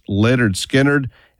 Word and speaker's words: Lynyrd Skynyrd: But tonight, we are listening to the very first Skynyrd album, pronounced Lynyrd Skynyrd.